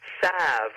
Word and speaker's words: salve: And it is now, and it's a funny word to say out loud because it's pronounced in different ways, which I kind of like the fact that it has different voices potentially in it, but it's the word salve or salve, S-A-L-V-E.